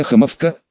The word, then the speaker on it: Хомовка: Давненько деревня Хомовка, ударение на первый слог, не видела такой картины.